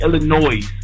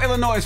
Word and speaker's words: Illinois: You kept saying Illinois. I love you, bro, but the S is silent, my man. – Spell Illinois for me, brother.